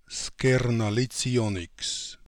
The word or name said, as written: Skernalits ionniks